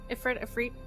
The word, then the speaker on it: Ifrit: And he is an Ifrit. Is that how you pronounce that? Make sure that's right. – Yeah, Ifrit.